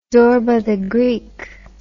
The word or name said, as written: Zorba the Greek